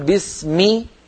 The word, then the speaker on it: بسم: لا بد أن نقول بسمي بكسر الميم كأننا ننطق ياء لأن الكسرة بنت الياء،